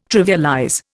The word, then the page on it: trivialize: Indian